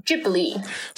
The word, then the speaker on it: Ghibli: Ghibli, the like main Ghibli.